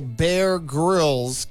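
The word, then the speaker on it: Grylls: Now you have to pronounce the grills with a hard G like a grill. Emphasize the first syllable, bear grills. Keep the Y and the grill soft like grills without the I. Practice saying it together smoothly. Bear grills. Bear grills. So his last name is Grills.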